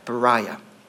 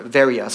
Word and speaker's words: Berea: Because verse 10, I'll, I'll read, it, says this that brothers immediately sent Paul and Silas away by night to Berea. or Veria, someone, some would pronounce it different.